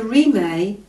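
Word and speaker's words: Rimé: The Rime, pronounced Rime and meaning no boundaries,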